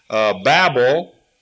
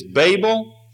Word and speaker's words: Babel: I looked it up, and there are two pronunciations. One is Babel, based on the way we would pronounce it in English. And the other one is based on the Hebrew pronunciation, because the first vowel A in Hebrew is actually a comet. Now, I know that you've been waiting to hear that. And so it's pronounced ba or ba, and the accent is on the last syllable, Babel.